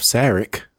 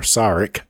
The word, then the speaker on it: Sarek: The episode features the first appearance of Sarek, or Sarek. I hear it pronounced both ways.